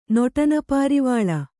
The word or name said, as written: ನೊಟನಪಾರಿವಾಳ